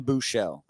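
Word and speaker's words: Buechele: It seems like there's an opportunity to say his name correctly. – Yeah, there you go. – Shane Bouchel. I said Buschelli on like 2 shows, and I couldn't.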